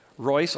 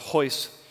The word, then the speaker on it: Royce: And at that first match, Royce, also it's written like Royce, but it's pronounced Hoyce, Gracie, he actually came in.